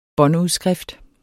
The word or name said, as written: båndudskrift